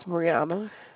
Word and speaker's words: Breunna: My name is Brianna. – How you spell that? – B-R-E-U-N-N-A. – Hold on, B-R-E? – U-N-N-A.